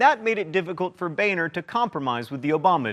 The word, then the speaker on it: Boehner: Bolstered by 80 or so new Tea Party congresspeople, the Republicans took control of the House in 2010, and John, it's pronounced Boehner, became the Speaker of the House.